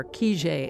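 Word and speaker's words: Kijé: Lieutenant Kijet, or Kijet as it's pronounced in Russian, was a book and then a movie about an army officer who never existed.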